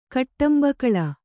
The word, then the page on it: ಕಟ್ಟಂಬಕಳ: kannada; kaṭṭambakaḷa